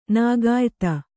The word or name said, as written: ನಾಗಾಯ್ತ